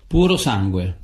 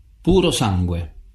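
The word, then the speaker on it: Purosangue: Puro sangue. Puro sangue. – Puro sangue. – Wanna play the game? – Yeah. – There we go. – Puro sangue.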